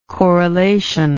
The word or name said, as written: correlational